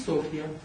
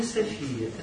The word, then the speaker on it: Софья: Для нас с вами одно имя, но будем произносить Софья. Все, у владычиц это жесткое идет различение. Это не София, это Софья.